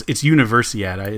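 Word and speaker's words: Universiade: at the 1995 Summer Universidad, which is basically a competition globally. That's a combination. Oh, it's Universiad is how you pronounce it. I'm sorry, because it's a combination of Olympiad and University.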